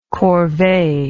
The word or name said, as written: corvee